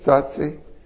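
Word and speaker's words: Tsotsi: Sotsi.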